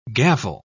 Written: gavel

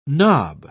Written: nob

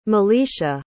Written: malisha

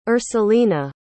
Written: ursulina

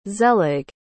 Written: zelig

